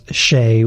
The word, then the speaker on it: Xie: And that's Shea, which is spelled X-I-E.